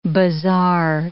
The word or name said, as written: bizarre